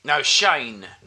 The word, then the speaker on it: Shane: Well, Shame's a great film, but it's not Shame. – Nearly mispronounced his favourite film. – What's it about, Shane? Shane, not Shame.